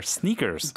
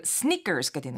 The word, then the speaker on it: sneakers: 예, 발음을 좀 더 길게 하셔야 돼요. – Sneakers. – Yeah, I'm wearing sneakers. – I'm wearing sneakers. – That's right. – 아, 이거 너무 sneakers 이렇게 짧게 하면 안 되고 sneakers라고 해야 되는구나.